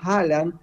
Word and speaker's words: Haaland: También cuando ustedes dicen Haaland, es correcto, pero las dos A juntas es O.